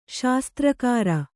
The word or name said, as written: ಶಾಸ್ತ್ರಕಾರ